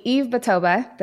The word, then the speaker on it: Yves Batoba: Today I have with me Yves Batoba.